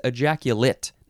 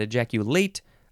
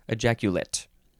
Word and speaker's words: ejaculate: Okay, the second form of this word is pronounced differently. It is ejaculate, not ejaculate, ejaculate. Spelled the same, though.